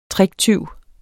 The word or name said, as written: tricktyv